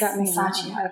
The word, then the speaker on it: Masaccio: He was around the same time as, I'm going to butcher his name, Masaccio? – I don't know. – Yes, Masaccio.